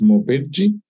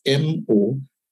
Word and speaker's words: Mobedji: And my last name is Mobedji. That's spelled as Mobedji.